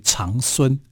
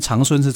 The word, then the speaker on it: 长孙: 是、啊，所以有人曾经把这个长孙呢、啊，就念成为长孙。嗯哼，啊，呃。可是念成长孙是错的，一定要念长孙啊，因为长大的长。